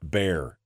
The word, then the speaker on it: Bexar: Bexar is pronounced Bear, says Gordon.